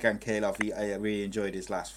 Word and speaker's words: Ankalaev: The Russian, I can't say his name, but Ankalaev.